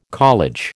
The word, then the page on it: college: American English